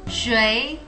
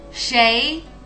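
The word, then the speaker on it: Shui (谁): Questo significa chi. In realtà ha due pronunce. La prima è quella che hai detto adesso. L'altra è? – Shei. – In realtà queste due pronunce sono intercambiabili. Non c'è una differenza di significato.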